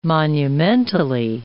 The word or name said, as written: monumentally